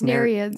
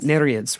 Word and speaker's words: Nereids: And we talked about the Nereids, or Nereids? – Nereids. – Nereids, right. Thank you. Thank you for correcting me.